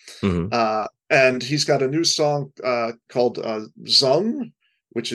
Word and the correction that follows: Zunge: Let me get the spelling of it in case I'm saying it wrong. Zung, Zunge.